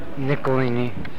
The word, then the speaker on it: Nicolini: Nicolini. – How do you spell that? – N-I-C-O-L-I-N-I.